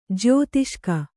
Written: ಜ್ಯೌತಿಷಿಕ

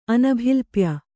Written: ಅನಭಿಲಪ್ಯ

